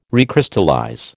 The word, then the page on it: recrystallize: American English